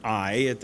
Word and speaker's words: Ai: Now, I use the term Ai. I believe that is the proper pronunciation of it. When you think of the European / Mediterranean pronunciation of languages, which would include mostly part of the way the Hebrew language would flow, the A-I together would be I.